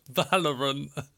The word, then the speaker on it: Valorant: Valent. No. – Well, that was close. – Valent. Tell me what it is. – Valorant. – Valorant. Okay, Valorant.